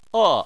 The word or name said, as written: e4